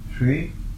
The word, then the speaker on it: Sri: Sri, pronounced Sri, is a Sanskrit honorific,